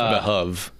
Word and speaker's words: behoove: I don't think any of us said behoove. – The DM did, and I am a character that is fourth wall breaking. – It's pronounced behove.